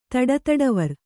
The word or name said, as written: ತಡತಡವರ್